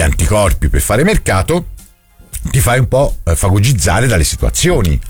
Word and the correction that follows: fagocitare: Non si dice fagocizzare. – Fagocitare? – Quello lì.